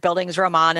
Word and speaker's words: Bildungsroman: Yeah, and the pronunciation is pretty good. I mean, it's a German word, Bildungsroman,